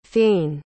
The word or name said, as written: fayne